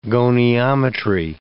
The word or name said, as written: goniometry